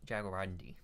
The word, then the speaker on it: jaguarundi: Jaguarundi. – Jaguarundi. Okay. I had it right the first time. Go me. All right. This is going to be a mess. Their scientific name is Herpalurus Jaguarundi.